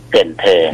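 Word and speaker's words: Cần Thơ: เกินเธอ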